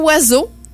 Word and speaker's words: oiseau: Le mot oiseau, avec 7 lettres, c'est le plus long mot dont on ne prononce aucune des lettres. Donc, le O, le I, le S, le E, A, U, X, on prononce pas ça. Donc, c'est le son oua, le son Z, le son O.